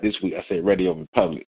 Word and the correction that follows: Radio Public: Did I still say it wrong? – It's Radio Public. – See, you keep adding the re on it. I don't know why you keep adding the re part on it.